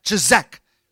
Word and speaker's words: chazak: Everyone say it like chazak.